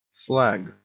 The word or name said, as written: slag